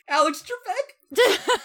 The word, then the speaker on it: Alex Trebek: God, fuck you, Alex Tabak. Fuck me up with that. – You didn't even say his name, Alex Tabak. Alex Trebek.